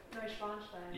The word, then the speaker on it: Neuschwanstein: Neuschwanstein, is that how you say it? Go ahead, you say it. – Neuschwanstein. – Yeah.